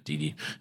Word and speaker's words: Didi: Is that how you say it? Didi, I think? – I think so, yeah. – Yeah, Didi.